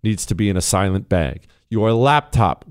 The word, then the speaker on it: SLNT: It's S-L-N-T, but it's pronounced silent, a silent bag.